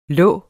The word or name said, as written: lig